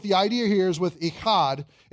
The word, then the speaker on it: Echad: This is the word, if you want to write above it in Hebrew, E-C-H-A-D, Echad. E-C-H-A-D, Echad is how you say it. And that C-H sound is what's known as a guttural in Hebrew, which gives you an opportunity to make gross sounds and sound smart.